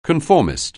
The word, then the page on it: conformist: anglais